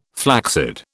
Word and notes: flaccid: British English

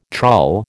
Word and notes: trull: American English

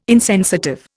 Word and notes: insensitive: Indian

